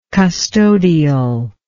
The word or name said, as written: custodialism